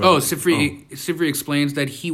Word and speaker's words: Sifri: Okay, elaborating on the comment of Safira on the uh, Safari. How do you pronounce that? Sifri. Sifri. Thank you.